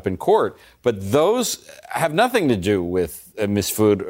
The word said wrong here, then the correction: Mifsud: How do you say his name? – Mifsud. – Mifsud or any of that.